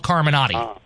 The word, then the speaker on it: Carmenaty: and then it was changed to A-T-Y. So my last name is spelled C-A-R-M-E-N-A-T-Y. That's how you spell Carminati.